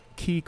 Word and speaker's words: Quay: This one is called Key Coffee, spelled Q-U-A-Y. – I was waiting to make sure that you pronounced it correctly, and bravo, you did it excellent. – I argued with a barista for about 10 minutes that there's no way that Q-U-A-Y should be pronounced.